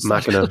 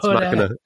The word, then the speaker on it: Machina: I'm not quite sure how you pronounce Machina. – That. Language isn't my strong suit. – But, it's Machina.